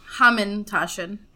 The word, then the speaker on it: Hamantaschen: Hamantaschen.